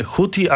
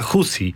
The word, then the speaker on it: Houthi: Monitoring bliskowschodni, panie ambasadorze, podpowiada mi, że nie Houthi, a Husi, ale to już jest taka wymowa bardzo, bym powiedział, ortodoksyjna w tym momencie, bo przyjęta jest w naszych mediach nie tylko wypowiedź Houthi, prawda, ale to już tak...